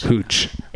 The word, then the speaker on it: hooch: So Hooch, it's spelled H-O-T-C-H? – No, H-O-O-C-H. – Hooch. – It's Hooch.